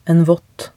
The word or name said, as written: en vott